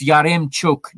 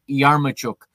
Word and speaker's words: Yaremchuk: It's Yaremchuk, not Yarmuchuk.